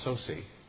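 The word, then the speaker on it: Saucy: actually his name is pronounced Sosi, who a longtime faculty member and a writer from Talbot Theological Seminary out in Los Angeles.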